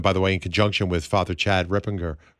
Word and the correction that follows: Ripperger: How do you say his name, Maggie? Do you know, is it Ripinger? It's Ripinger, right? Father Chad Ripinger, I'm pretty sure. – Ripberger, yeah. – Ripberger, thank you very much.